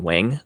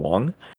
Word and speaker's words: Wang: And I did say Sean Wang last time. That is not how you pronounce it, apparently. It's Sean Wong, even though it's spelled W-A-N-G.